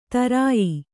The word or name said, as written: ತರಾಯಿ